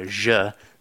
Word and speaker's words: Aja: And I think one of the other groups from Seattle, Je, it's spelled A-J-A, but apparently the A's are silent.